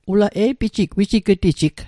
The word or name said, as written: wijig'tijig